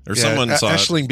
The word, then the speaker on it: Aisling: Listen, I just learned this week that A-I-S-L-I-N-G is pronounced Aisling.